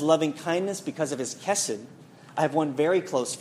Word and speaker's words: chesed: It's a Hebrew word which is pronounced chesed.